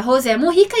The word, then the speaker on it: Mujica: Bene, então é importante aqui lembrar que o correto seria José Mujica, e a gente pode ouvir na Itália as pessoas falarem em diferentes versões aí, dependendo de como elas vão conseguir pronunciar o J.